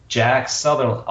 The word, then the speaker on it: Jack Sutherland: It is Jack Sutherland.